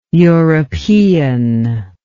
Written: europeanly

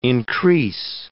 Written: increase

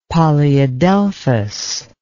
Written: polyadelphous

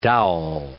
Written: dowel

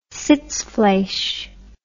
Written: sitzfleish

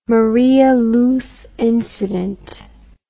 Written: Maria Luz incident

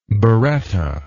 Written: birretta

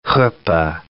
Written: chuppah

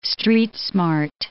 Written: street-smart

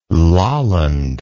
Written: laaland

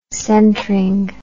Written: centring

